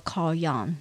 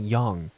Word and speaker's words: Jung: ，and also Carl Jung 荣格，其实他们对神神话对他们的理论的影响是非常的大。 – 哎，我想问一个有关发音的问题，嗯、那个因为荣格是 J U N G 嘛，对不对？ – 对，可是他不念 John，他要念 Young。